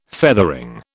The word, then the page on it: feathering: British English